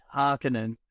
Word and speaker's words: Harkonnen: I listened to recordings of of Herbert, and he said Harkonnen, and ah.